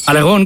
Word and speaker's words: Aragón: Aragón con acento en la O.